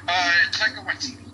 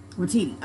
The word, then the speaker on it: Waititi: Taika Waititi. – Waititi.